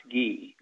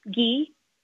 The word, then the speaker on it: ghee: It's pronounced ghee. – Ghee? – Yeah, you're saying like gee whiz.